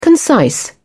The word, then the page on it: concise: British English